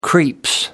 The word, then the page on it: creeps: British English